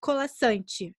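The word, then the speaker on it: Colasanti: que é baseado num conto da Marina Colassante. É assim que pronuncia. Isso. É.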